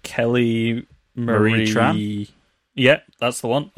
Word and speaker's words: Kelly Marie Tran: Kelly Marie Tran.